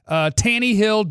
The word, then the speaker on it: Tannehill: By the way, some listeners hate that we overpronounce the E in Tannehill, but I think it's fun. Tannehill, Derrick Henry, and A.J.